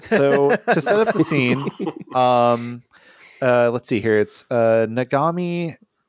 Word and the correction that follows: Nagamine: Am I saying it right? – Nagamine. And Yonemori sitting in this bar, which we've just jumped cut to.